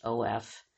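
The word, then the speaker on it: Nieuwhof: I'm sure I'm pronouncing that wrong. It's Neuwauf.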